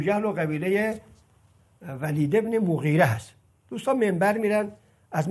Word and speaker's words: مغیره: حالا ممکنه ما نادرست به اسم مفعول تلفظ کنیم. مغیره درسته، ولید ابن مغیره.